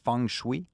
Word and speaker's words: feng shui: La prononciation, je crois, c'est feng shui. Mm-hmm. Ça s'appelle F-E-N-G-C-S-H-U-I. Je répète, mêlé, là. – F-E-N-G. – Oui. – S-H-U-I.